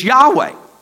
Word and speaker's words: Yahweh: It's Yahweh.